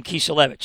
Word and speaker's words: Kiselevich: And yes, that's the way you pronounce his name, Kiselevich.